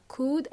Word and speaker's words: could: Remember, could and would, L is silent. We don't pronounce the L.